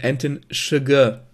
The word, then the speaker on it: Chigurh: Ich glaube, im Film wird da ausgesprochen Anton Chigurh.